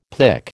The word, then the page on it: pic: American English